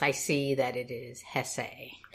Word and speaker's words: Hesse: And for this episode, we read Beneath the Wheel by Hermann Hesse. Hesse? One of those two. It's two syllables. It's not Hess.